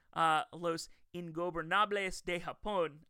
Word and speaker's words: Los Ingobernables de Japón: They are another group of individuals who includes Zack Sabre Jr. I have butchered this name every time I've tried to say it. I'm going to try it one more time. Los Ingobernables de Japón. I got it right.